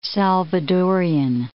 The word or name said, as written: Salvadorian